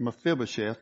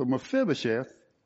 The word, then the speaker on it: Mephibosheth: We're going to see at the end of this that Mephibosheth, and I'll probably say it Mephibosheth because that's the way the English would pronounce it, but the I, best I know in Hebrew, is a long E or double E.